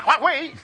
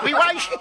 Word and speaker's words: Huawei: Oye, pues se pronuncia Huawei. Se pronuncia Huawei, Awis, Agais, Ojo, Wangay.